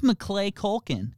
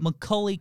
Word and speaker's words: Macaulay Culkin: Who's Macaulay Culkin? – How do you say his name? – Macaulay Culkin.